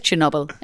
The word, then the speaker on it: Chernobyl: But it is Chernobyl. And I said it correctly, Chernobyl.